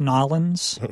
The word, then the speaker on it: New Orleans: So this is a clip from the National World War II Museum in New Orleans. – I'm going to say from South Carolina, it's actually pronounced Nolens, but...